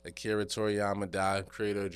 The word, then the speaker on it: Akira Toriyama: I'm going to just say Akira Toriyama.